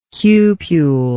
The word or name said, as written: cupule